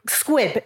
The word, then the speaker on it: squib: Why is it not a damp squid on your head? A squid sitting on your head? – Yeah. – That's how you would feel. What is a damp squib? Squib, isn't it? That's how it's said.